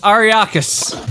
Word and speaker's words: Arrakis: Are we just going to pronounce that Arrakis like the fucking desert planet? – It's Arrakis.